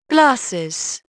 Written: glasses

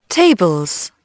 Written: tables

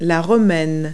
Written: romaine

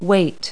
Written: weight

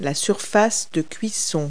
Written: cooktop

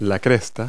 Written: crest

